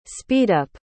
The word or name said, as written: speedup